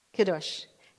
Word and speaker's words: Kiddush: How do you say it? Kiddush? Kiddush. K-I-D-D-U-S-H.